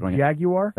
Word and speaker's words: Jaguar: Jaguar? – That's what it's called. – That's how you say it. – Really? – Yeah. Specifically, yeah. That's how you officially say it.